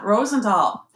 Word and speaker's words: Rosendal: was tell us about Rosendal. Did I say it right? Yeah, Rosendal.